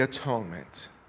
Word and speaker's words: atonement: And not only so, but we also joy in God through our Lord Jesus Christ, by whom we have now received the atonement. The Puritans used to pronounce the atonement differently than we do. They pronounced it the at-one-ment. Because it was at that time that God and man was at one.